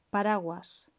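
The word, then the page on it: paraguas: Spanish (Spain)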